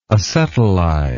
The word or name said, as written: acetylizer